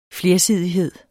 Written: flersidighed

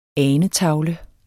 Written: anetavle

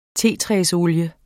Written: tetræsolie

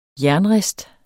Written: jernrist

